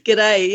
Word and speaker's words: G'day: Did I say that right? Good day, mate. – Good day, mate. – You did that a little too well. – A little, yes. – G'day. Yeah, that's pretty much it.